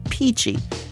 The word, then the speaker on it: Pici: The restaurant was Fresco Italian Cafe, and the dish was a Tuscan pasta called Pici. That's spelled P-I-C-I.